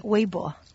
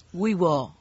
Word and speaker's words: Weibo: Weibo. – Weibo.